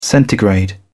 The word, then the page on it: centigrade: British English